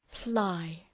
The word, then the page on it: ply: plaı